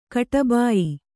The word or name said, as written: ಕಟಬಾಯಿ